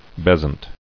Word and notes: Besant: Bes·ant

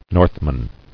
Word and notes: Northman: North·man

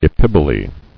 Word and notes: Epiboly: e·pib·o·ly

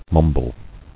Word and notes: Mumbler: mum·ble

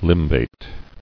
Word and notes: Limbate: lim·bate

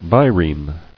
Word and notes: Bireme: bi·reme

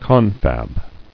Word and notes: Confab: con·fab

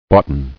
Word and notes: Boughten: bought·en

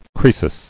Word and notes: Croesus: Croe·sus

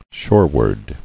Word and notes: Shoreward: shore·ward

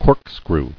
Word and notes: Corkscrew: cork·screw

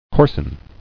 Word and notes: Coarsen: coars·en

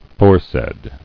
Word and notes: Foresaid: fore·said

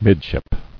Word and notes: Midship: mid·ship

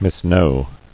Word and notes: Misknow: mis·know

